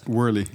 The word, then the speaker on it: Worley: is er op allerlei manieren subsidies, eh, partijen, hè, die het Worley, Worley, Worley, hoe spreek je dat uit? Worley, ja, het is een Australische partij.